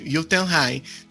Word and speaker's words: Jotunheim: Esse eu sei pronunciar porque eu assisti Thor, gente. Jotunheim.